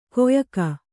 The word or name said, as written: ಕೊಯಕ